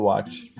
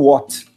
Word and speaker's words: Watt: Só que W, até pouco tempo atrás, quando a gente era menos americanófilo, o VAT a gente pronunciava com o som de V, né?